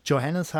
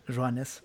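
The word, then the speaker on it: Johannès: Also gerne jeder mal einfach Highlights von Marin Johannes, die nicht Johannes heißt, sondern tatsächlich im Französischen, ist wahrscheinlich auch das einzige Wort, was ich beherrsche, Johannes ausgesprochen wird.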